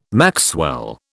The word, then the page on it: maxwell: British English